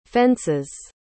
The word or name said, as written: fences